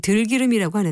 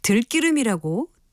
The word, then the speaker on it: 들기름: 들깨로 짠 기름은 들기름이라고 하는데 이것을 들기름이라고 된소리로 발음하는 분들이 꽤 있는 것 같습니다. 하지만 이것은 된소리로 발음할 이유가 없기 때문에 표기 형태와 같이 들기름으로 발음해야 합니다.